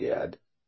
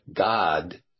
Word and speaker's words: Gad: But, of course, we're going to find out that Gad is not pronounced Gad, but rather it's pronounced something else. Gad is not pronounced Gad like in Ad, but God like in God.